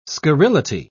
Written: scurrility

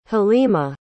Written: halima

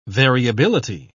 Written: variability

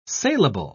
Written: salable

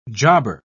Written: jobber

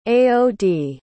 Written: aod

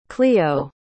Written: cleo

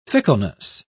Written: fickleness

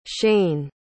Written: shane

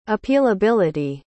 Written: appealability

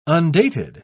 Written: undated

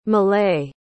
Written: millay

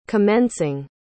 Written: commencing

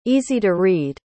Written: easy-to-read

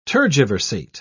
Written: tergiversate

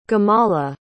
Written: gemala

